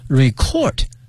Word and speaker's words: record: 这个发音同学们一定要小心了， record 是动词，表示录音哈，录制的意思。作为名词呢，它的发音呢是 record，可以指呢我们说记录或者说唱片等等。